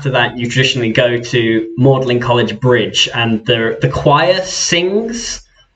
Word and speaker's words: Magdalen College: But it's interesting because well, so I mean, that's why it's No, that's why it's Magdalen College, even though it's spelled Magdalen College, because the tradition of pronunciation far predates the spelling.